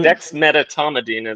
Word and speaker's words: dexmedetomidine: Dexmedetomidine? Is that how you say it? – Dexmedetomidine is how I've always heard it said and how I've said it.